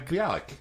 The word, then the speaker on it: Bialik: Bilicek. She was in Blossom. – Oh, Maya Bialik. Maya Bialik.